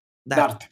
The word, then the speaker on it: DART: ¿Qué te parece si hablamos un poco de la misión eh, Dark? no, DART. ¿Cómo se pronuncia? – DART. – D-A-R-T. – Correcto.